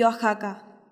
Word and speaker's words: Oaxaca: Los mexicanos prefieren escribir México y Oaxaca, pero siempre pronunciarán México y Oaxaca.